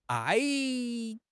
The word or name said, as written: 挨